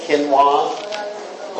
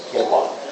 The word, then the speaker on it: quinoa: Something I eat is called quinoa, or you may pronounce it as quinoa. But actually the right pronunciation is quinoa.